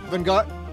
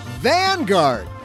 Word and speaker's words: Vanguard: It's Vanguard. Oh, emphasis on the vet. Got it. Vanguard.